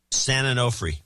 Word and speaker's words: San Onofre: And the real deal is San Onofre. Is that how you pronounce it? – San Onofre.